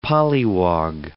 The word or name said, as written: polliwog